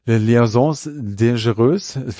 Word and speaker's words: Liaisons Dangereuses: Dan Silver hatte ihn als Schurken schon in der Broadway-Version von Liaisons Dangereuses.